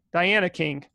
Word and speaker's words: Diana King: I might have said that name wrong. Hold on one sec. Not Diane King. Yeah, Diane King. Diana King.